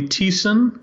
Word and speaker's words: Thiessen: Thiessen. – Thiessen. – It's a German. Yeah, it's a German name. The H is silent, and the IE says E.